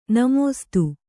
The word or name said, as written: ನಮಸ್ತೇ